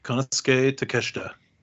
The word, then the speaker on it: Konosuke Takeshita: Konosuke Takeshita.